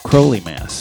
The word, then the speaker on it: Crowley Mass: Before that, Hilmar Orn Hilmarsson and Current 93 did, well, Crowley Mass, because it's rhymes with holy, not with Fowley, who produced The Runaways.